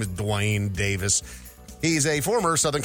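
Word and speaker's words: Duane: Dave, get his name right. It's Dwayne.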